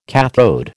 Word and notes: kathode: American English

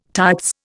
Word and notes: tights: Indian

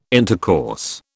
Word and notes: intercourse: British English

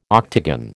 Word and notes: octagon: American English

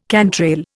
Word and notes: cantrail: Indian

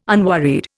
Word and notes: unworried: Indian

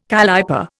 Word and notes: calliper: Indian

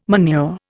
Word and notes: manure: Indian

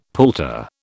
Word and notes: palter: British English

